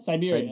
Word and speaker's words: Siberia: what's that place in Russia that's like Siberia, right? Si- si- Siberia? – Siberia.